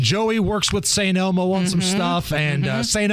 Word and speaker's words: St. Elmo: Thank you for saying it right, because I don't want the mean tweets to come in. I can't believe they put an S at the end of it.